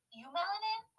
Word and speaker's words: eumelanin: It's eumelanin, right? – That's how I would say it, yeah.